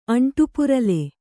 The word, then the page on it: ಅಂಟುಪುರಲೆ: aṇṭupurale